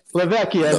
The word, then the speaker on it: Levecchia: Is it, how the fuck do you say your last name again? Levecchia, right? – Levecchia. That's my real last name. Yeah. – Levecchia. I always want to say Levecchia. – Well, Vecchia means old in Italian.